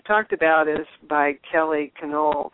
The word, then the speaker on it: Canole: She says people pronounce it in a variety of ways. She said people that love the Italian pastries call it cannoli, but it's canole. It's, I think it's Irish or Scottish.